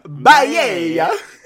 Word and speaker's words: Baiae: So our wild tale begins in the bay town of Baiae, and I'm going to pronounce it that way for the rest of the time, but for anyone who wants to correct me, it's spelled B-A-I-A-E. – Baiae. – Baiae. Baiae. – That sounds right.